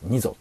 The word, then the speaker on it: 二三: これ二三と書いて二蔵って読むんですけど、ひふみの一じゃないやつですね。